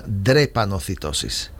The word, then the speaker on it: drepanocitosis: Hace unos días ha saltado la prensa escrita, cada 6 semanas le cambian la sangre a un grupo de niños con depranocitosis. No sé si lo he pronunciado bien. – Es una enfermedad que se llama drepanocitosis. ¿Vale? La drepanocitosis es una enfermedad que es...